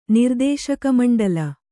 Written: ನಿರ್ದೇಶಕಮಂಡಲಿ

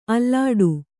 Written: ಆಲೀಢ

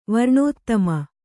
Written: ವರ್ಣೋತ್ತಮ

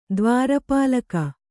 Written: ದ್ವಾರಪಾಲಕ

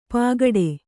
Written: ಪಾಗಡೆ

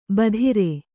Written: ಭದ್ರೆ

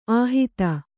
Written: ಆಹಿತ

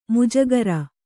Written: ಮುಜಗರ

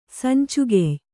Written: ಸಂಚುಗೆಯ್

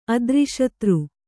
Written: ಅದ್ರಿಶತ್ರು

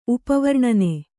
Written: ಉಪವರ್ಣನೆ

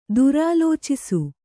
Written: ದುರಾಲೋಚಿಸು

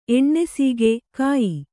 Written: ಎಣ್ಣೆಸೀಗೆ(ಕಾಯಿ)

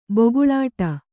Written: ಭುಗಿಲಿಡಿ

